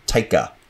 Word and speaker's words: Taika: Taiki? How do you say his name? – Taika.